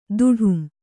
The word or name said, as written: ತಡಂ